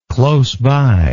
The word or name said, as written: close by